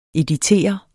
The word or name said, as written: editere